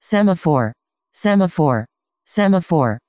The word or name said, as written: semaphore